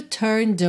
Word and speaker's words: turned: O E de turned não é pronunciado, tá bem? Então, você vai do N pro D e usa o som do D para juntar em away, turned away.